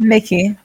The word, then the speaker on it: Mickey: Is it Mikey or Mickey? It's Mickey, right? – Mickey. Okay, like good. Mickey Mouse.